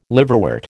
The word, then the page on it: liverwort: American English